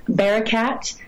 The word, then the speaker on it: Barakat: My name is spelled a little bit different than most, but it's Hannah, H A N N A, Barakat, um, which is B A R A K A T, and they can find me on all the things.